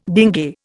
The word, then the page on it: dinge: Indian